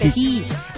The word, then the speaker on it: petit: Petit. Porque aprendí que la T al final en francés no se pronuncia.